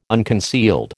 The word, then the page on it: unconcealed: American English